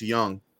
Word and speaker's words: Jung: Am I saying his name right? – It's Young.